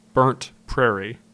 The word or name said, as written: Burnt Prarie